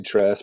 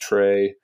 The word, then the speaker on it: Tres: I mean, obviously my name's Tress and everyone wants to call me Tress, but it's pronounced Trey.